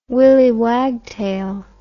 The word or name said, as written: willie wagtail